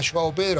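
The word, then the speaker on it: João Pedro: Joao Pedro, así se pronuncia.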